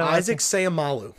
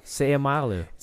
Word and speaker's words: Seumalo: Isaac Seumalo. – Seumalo.